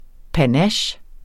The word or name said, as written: panache